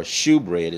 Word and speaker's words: shewbread: Shalt thou make them, and thou shalt set upon the table showbread, or shewbread, as some people pronounce it, before me always.